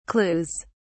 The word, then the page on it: clues: British English